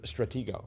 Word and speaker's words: Stratego: Stratego, uh-huh. Uh-huh. I think it's a hard G, but anyway.